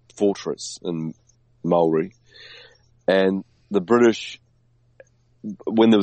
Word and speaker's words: Māori: Like the Maori or Māori, as they meant to, you supposedly pronounce it, had a massive.